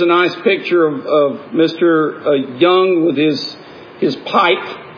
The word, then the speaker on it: Jung: And so he said he likes to quote Carl Jung, who was, or Carl Jung, I think it's pronounced with a Y.